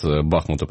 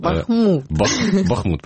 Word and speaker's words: Бахмут: Из описания проекта «Будущее Донбасса» сейчас на «Украинской правде» появилось только один город, Бахмуту посвящен. – Бахмут, правильно говорить, да, местные говорят Бахмут.